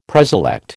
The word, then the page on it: preselect: American English